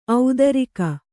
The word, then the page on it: ಔದಾರಿಕ: audārika